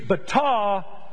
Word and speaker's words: batah: In the Hebrew, hope is the word B-A-T-A-H. And to me, the way that's pronounced, I did this in Bible study the other night, it's bata.